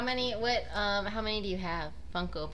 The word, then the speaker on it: Funko: Is that how you pronounce it? Funko Pop. – Yeah. – Okay, I've been calling it like Funke Pop, like Tobias Funke from Arrested Development.